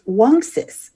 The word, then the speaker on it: Wunxus: Fidelia: Miss Raymond, I said that Uncas's name is correctly pronounced Wunxus, which means fox in English.